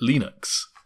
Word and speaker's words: Linux: But apparently, well, I always thought it was supposed to rhyme with the creator, Linus Torvalds. – Ah, so Linux. – Linux. But apparently he has said that you pronounce it Linux.